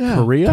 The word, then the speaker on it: Kariya: Like Kariya? – Kariya, yeah.